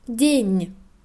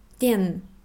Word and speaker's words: день: Tag, день, nicht den.